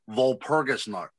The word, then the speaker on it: Walpurgisnacht: Okay, well that the W is pronounced with a V. Walpurgisnacht.